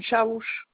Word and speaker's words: Chaouche: Madame Chaouche. – Comment ça s'écrit? – C-H-A-O-U-C-H-E.